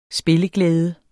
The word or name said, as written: spilleglæde